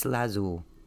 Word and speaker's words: lazuli: Lapis lazuli, make a decision. Okay. Lapis lazuli. Sorry, I said it wrong. Lazuli. Okay.